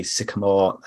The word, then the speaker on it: Sycamore: And if anyone on Twitter, we are Bitches with Beards, or it's Max J Sycamore. That's Sycamore spelled like the tree.